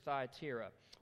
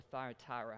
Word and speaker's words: Thyatira: And we're going to attempt to cover two today, the church at Pergamum and the church at Thyatira, or Thyatira, however you would like to say that, depending on where you're from, or the correct pronunciation.